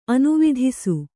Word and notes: ಅನುವಿಧಿಸು: anuvidhisu

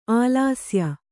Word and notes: ಆಲಸ್ಯ: ālasya

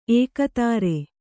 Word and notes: ಏಕೋದರ: ēkōdara

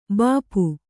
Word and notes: ಭೂಪ: bhū pa

